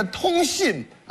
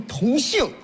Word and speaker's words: 通信: 这个通信啊，不能念成同姓哦，同姓不能念成通信哦，通信可以同姓，同姓的不一定通信哦。